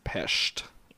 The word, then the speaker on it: Pest: It was Buda, and I'm actually pronouncing it wrong. It's Pest is how you're supposed to pronounce it.